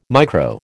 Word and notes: micro: American English

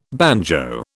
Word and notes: banjo: British English

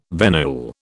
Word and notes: venule: British English

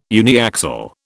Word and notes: uniaxial: British English

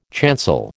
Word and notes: chancel: American English

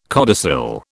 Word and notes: codicil: British English

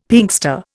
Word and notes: Pinkster: Indian

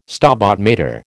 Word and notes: Stabat Mater: American English

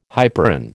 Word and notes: hyperon: American English

